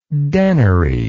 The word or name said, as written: denary